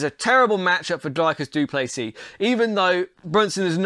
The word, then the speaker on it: du Plessis: Dricus du Plessis, he is a phenom from South Africa. The S is silent, and always he looks tired, but he's big, he's strong, he's good in every area.